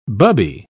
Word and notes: bubby: British English

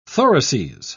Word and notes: thoraces: British English

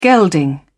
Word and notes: gelding: British English

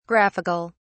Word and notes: graphic(al): British English

